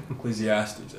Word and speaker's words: Ecclesiastes: And I'm probably actually, like last year, I read Ecclesiastes, I think.